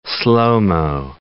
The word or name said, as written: slo-mo